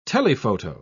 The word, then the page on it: telephoto: British English